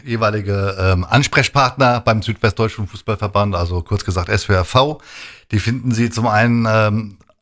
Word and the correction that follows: SWFV: Also ich muss es nochmal deutlich aussprechen, ja? SWFV. – Genau, SWFV, Südwestdeutscher Fußballverband.